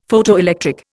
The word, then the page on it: Photoelectric: Indian